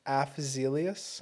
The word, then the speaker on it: Afzelius: Avzelius. Type that in and like. – Havi- Avzelius. Yeah, how do you say that? – Avzelius. Half. – Af. Oh, A F. – Afzelius.